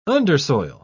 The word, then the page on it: undersoil: British English